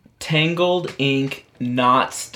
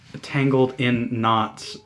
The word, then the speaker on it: Tangled in Knots: Tangled Inc. Knots Design Co. – That is wrong. – No, Tangled Inc. Knot, N-O-T-S, Knots Design Co. – Tangled in Knots Design Co.